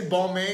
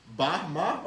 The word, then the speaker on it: Balmain: How do you say Balmain? Because Floyd Mayweather was like, I'm wearing Balmain Paris. – Balma. – Balma? – Yeah. – Bahma or like no. – B-A-L-M-A? – Balma.